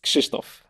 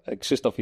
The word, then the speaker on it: Krzysztof: So his first name is hard to pronounce for English folks. That's for sure. And for anyone aboard, really. It's Krzysztof. Krzysztof is like a Polish translation, but once you see it, you wouldn't think that you read it this way.